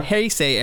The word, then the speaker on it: Heisei: I'm going to butcher this. The Heisei? – The Heisei era? – Heisei era.